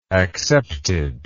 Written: accepted

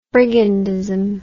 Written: brigandism

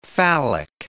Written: phallic

